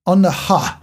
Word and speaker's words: on the huh: Is that how you say it? The huh? – On the huh.